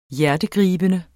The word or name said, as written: hjertegribende